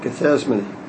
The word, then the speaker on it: Gethsemane: They went to a place called Gethsemane.